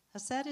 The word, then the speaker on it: chesed: It's also difficult to pronounce if you pronounce it properly because it has a little Hebrew thing at the beginning, you know, chesed, right?